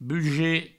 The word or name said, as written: buget